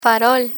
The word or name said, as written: farol